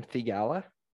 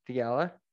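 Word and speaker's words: Theegala: At this moment, it's Cantlay's tied with, I don't know how to say his name, Thigala? – So, yeah, that sounds right. – Thigala, dash 15.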